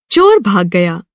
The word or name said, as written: चोर